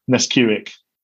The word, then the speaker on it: Nesquik: Is it Nesquik? It's a bit like that. – Oh, yes. Nesquik.